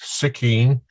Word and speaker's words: Suiceen: He talks about Suiceen, and he spells it S-U-C-K-I-N-E. – Right. – It's now pronounced Suiceen,